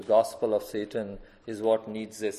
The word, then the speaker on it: Nietzsche: His name was Nietzsche. My pronunciation is done. I know how the Germans pronounce, I pronounce it, and probably this is right.